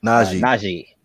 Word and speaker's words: Najee: Najee.